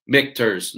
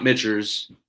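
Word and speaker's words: Michter's: Michters, not Mitchers. I said that wrong last time.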